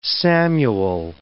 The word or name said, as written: Samuel